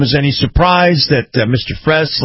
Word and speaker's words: Frese: His name is Kyle, and I believe it's pronounced Fress, F-R-E-S-E. So I'm going to pronounce it that way, Fress.